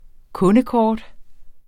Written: kundekort

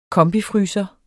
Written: kombifryser

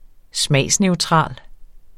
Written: smagsneutral

